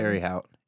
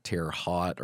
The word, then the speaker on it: Terre Haute: I didn't even know how to pronounce it for the longest time. I thought it was like Terre Haute or something. I had no idea.